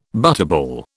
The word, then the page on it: butterball: British English